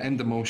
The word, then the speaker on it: Endemol: But going back to Endemol, is that how you pronounce it? – Yeah, Endemol Shine.